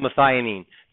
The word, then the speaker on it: methionine: It's called methionine. The start is methionine. It's always methionine. Am I pronouncing it right? Methionine? Yeah.